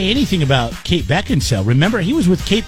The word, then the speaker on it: Beckinsale: Beckinsale. That's how you're supposed to pronounce it.